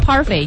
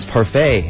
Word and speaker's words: parfait: 老让我想起来了这个著名的首富。但是你这个 parfait 应该怎么拼呢 Parfait, I think, is parfait and is P-A-R-F.A-I-T.